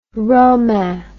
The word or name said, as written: romain